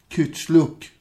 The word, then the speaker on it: Kuchlug: Det är bara det att det uttalas ju, du uttalar förmodligen helt rätt. Kuchlug, ja.